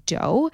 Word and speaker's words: Deux: So this week's hot tip is actually from one of my favorite brands, Dough, and it's spelled D-E-U-X.